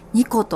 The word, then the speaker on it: 和: はい、このニコというお店のお名前がですねとても印象的だったんですが、和と書いてニコと読むということで、お名前の由来そしてお店の中のですねイメージなども教えていただけますでしょうか。